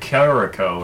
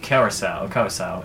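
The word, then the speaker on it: Curaçao: There's this liqueur called Curacao, and I always called like Curacao or something like that. And what? – It's pronounced Curacao.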